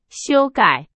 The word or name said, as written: 修改